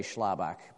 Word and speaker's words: Schlabach: And it was hard for them to say Schlabach because it just is a hard name.